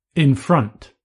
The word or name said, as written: in front